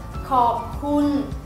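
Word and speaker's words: khọp khun (ขอบคุณ): cảm ơn là khọp khun khọp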